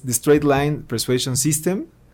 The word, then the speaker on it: The Straight Line Persuasion System: que corrígeme si lo digo mal, pero eh, es The Straight Line Persuasion System, o en español la línea recta de la persuasión. – Es correcto.